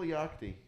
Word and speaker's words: Yachty: I said Little Yachty. – Yachty. – Oh, it's pronounced Yachty? – Yeah.